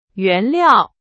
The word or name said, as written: 原料